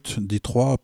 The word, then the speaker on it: Detroit: Et il vient aussi d'une ville, Detroit, pour prononcer à la française,